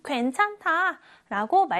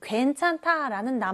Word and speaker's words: 괜찮다: 이 낱말에는요, 겹받침이 있습니다. 여기 니은 히읗이 있죠? 이것에 주의하면서 읽어볼게요. 괜찮다. 괜찮다. 제일 처음 괜은 그대로 발음을 하면 됩니다. 괜. 그런데 찬다에서 니은 히읗 겹받침이 있죠? 이런 경우에는 둘 중에 하나의 소리만 나는데 대부분 앞에 있는 자음 괜찮 니은의 소리 니은이 소리가 납니다.